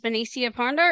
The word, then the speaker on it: Benecia Ponder: Her name is Benecia Ponder, and if I mispronounced your name, please forgive me for that.